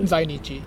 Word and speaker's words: Zainichi: Zainichi. – Yeah. And this refers to long-term Korean residents of Japan who can trace their roots to Korea under Japanese rule. – Mm. – So Zainichi